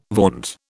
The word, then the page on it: vaunt: British English